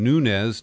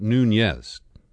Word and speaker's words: Nunes: As a Republican, he pronounces his name Nunes, not Nunez, as most California Latinos might.